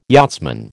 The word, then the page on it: yachtsman: American English